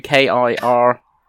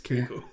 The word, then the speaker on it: Kiriko: Oh, you have mispronounced her name. – Oh, I can spell it for you. Kiriko. – Okay, you just mispronounced it. You didn't know it. Yes, Kiriko.